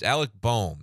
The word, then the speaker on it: Alec Bohm: It's Alec Bohm. That's how you say the man's name.